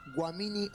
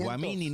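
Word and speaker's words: Guaminí: en la calle Guamini al 4500. – Guamini no, Guamini, hijo de... Guamini. – No, no, no, porque estoy hablando del coreano, el coreano lo pronuncia así.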